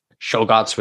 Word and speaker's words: Czolgosz: I don't know how to pronounce his name now all of a sudden. Czolgosz. It's Czolgosz. Leon Czolgosz. He was born in Michigan, 1873.